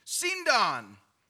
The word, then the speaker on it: sindon: I had to look that up this morning to make sure my pronunciation was correct. Sindon.